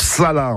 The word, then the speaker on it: chalas: Bref, une précision, en dauphinois, Chalas, ça se prononce Sala, en fait, Sala.